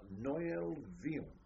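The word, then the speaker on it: Noyelle-Vion: And it rejoiced in the name of Noël Vion. N-O-Y-E-L-L-E. One word. Vion. V-I-O-N.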